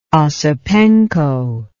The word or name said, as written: Osipenko